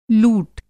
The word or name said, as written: लूट